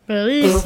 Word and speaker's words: Belize: B-E-L-I-Z-E? – Belize Navidad? – Belize Navidad. – What is it? How do you say it? – Belize.